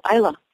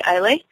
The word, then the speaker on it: Islay: Laagavulin Bay is a beautiful corner of the world in a little island just south of Scotland called Islay. Some people say Islay. I've heard it pronounced both ways.